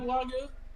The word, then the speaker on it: wagyu: Have you ever had Wagyu? Did I say it right? – Yeah, Wagyu.